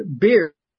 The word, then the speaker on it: bier: He came and touched the bier. – That's pronounced beer.